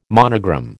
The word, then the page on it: monogram: American English